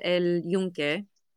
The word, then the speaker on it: El Yunque: It's called El Yunque. Correct me if I said that wrong.